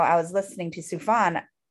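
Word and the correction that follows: Sufjan: um, am i pronouncing that right no Sufian, sufyan sufyan like Jan.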